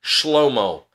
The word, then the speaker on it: Shlomo: And Solomon's name, I've said this before, but I'll tell you, the Hebrew pronunciation of these particular Hebrew letters is not Solomon. So if you say it in English, you may have heard this word in Hebrew cultures and circles, Shlomo. That would be his name, Shlomo.